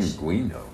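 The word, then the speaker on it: pingüino: Say pinguino. Say it in Spanish. – Pinguino.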